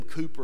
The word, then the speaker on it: Cowper: William Cooper, and I've quoted him before, was a great hymn writer in the 1700s or 1800s. And if you look him up, it's spelled William Cowper, C-O-W-P-E-R.